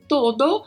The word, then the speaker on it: todo: Todo, que também tem essa pronúncia, né? Com o O bem fechado, todo, que significa tudo, né?